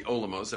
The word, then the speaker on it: olam: as filtered through what we call the olamos. Everyone practice that term, olamos, together? Olamos. Come on, let's get active here. Olamos, right? How do you say world in Hebrew? Olam.